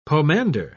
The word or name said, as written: pomander